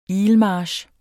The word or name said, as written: ilmarch